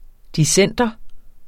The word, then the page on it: dissenter: [ diˈsεnˀdʌ ]